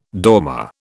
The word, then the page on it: dormer: British English